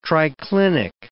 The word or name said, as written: triclinic